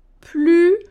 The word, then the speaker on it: plus: Maintenant, si je dis elle est plus gentille que sa sœur, Là, je ne prononce pas le S.